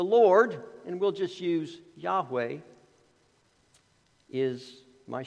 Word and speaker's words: Yahweh: It's been pronounced a variety of ways. Jehovah is sort of the older pronunciation. We sang a hymn that had Jehovah in that hymn. The, the more modern tr- pronunciation is, is Yahweh, but the important thing is not how we pronounce it.